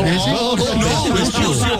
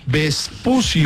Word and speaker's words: Vespucio: Ese American Prepucio. – No, Vespucio, Felipe. – ¿Cómo se dice? – Vespucio.